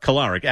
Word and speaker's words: Kolarik: Is that how you say it? Kolarik. – Kolarik. Adam Kolarik, a lefty.